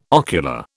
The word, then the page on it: ocular: British English